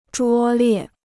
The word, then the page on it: 拙劣: zhuō liè